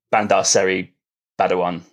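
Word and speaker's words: Bandar Seri Begawan: It's called, I'm not great at pronouncing this, is it Bandar Darussalam? – Okay. – Be wrong there. Bandar Seri Badawan, I think it's called. not great at pronunciation. – You're close.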